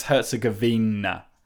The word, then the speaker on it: Herzegovina: I say Herzegovina, but it's Herzegovina.